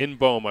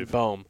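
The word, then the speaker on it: In-beom: I mean, Wangan Boehm. Is the am I even saying that correctly? – In Boehm, I believe. – In Boehm.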